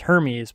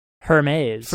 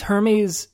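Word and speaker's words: Hermès: I thought it was pronounced Hermes, but I don't think that's correct, actually. They're pronouncing it in some really fancy, highfalutin way that I don't think I can. – Hermes.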